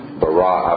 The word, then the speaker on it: bara: And I am told that the Hebrew word that is used there, bara, I believe is the correct pronunciation, means to create from nothing that was previously in existence.